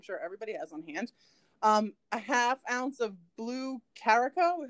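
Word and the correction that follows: Curaçao: It's spelled like Krakako or whatever, but I thought it sounds like the name of an island. – Yeah, it does. It's Curacao though.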